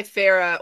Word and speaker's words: Farah: The H is pronounced in my name. I go by Farah or Canna Queen, you know, because that's who I am.